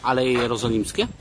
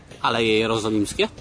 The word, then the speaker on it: Aleje Jerozolimskie: Aleje Jerozolimskie. – Again? – Aleje Jerozolimskie.